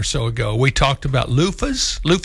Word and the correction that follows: luffa: Right, sure. – What's how you say it? – Luffa.